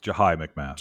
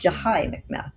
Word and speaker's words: Jahi McMath: And the story of is that how it's pronounced? Jahi McMath? – It's Jahi McMath. – Jahi McMath, thank you.